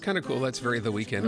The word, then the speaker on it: The Weeknd: The Weeknd. The Weeknd. Spelled without an E before kind. – Yeah, it's right, ND. Okay.